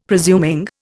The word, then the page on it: presuming: Indian